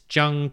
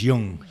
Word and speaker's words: Jung: So it's the Myers-Briggs personality test, but this is through Human Metrics Jung Typology Test. – It's pronounced Jung. Jung was a psychiatrist philosopher.